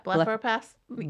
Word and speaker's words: blepharoplasty: What about blepharoplasty? Am I saying that correctly? – Yeah, blepharoplasty.